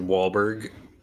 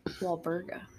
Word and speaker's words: Walburga: And I might be saying that wrong, but I said Wahlberg. – Walburga. – Walburga. – Yeah. It's German.